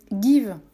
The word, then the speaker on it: Geev: Euh, give. Give, ça s'écrit G-E-E-V.